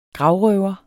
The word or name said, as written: gravrøver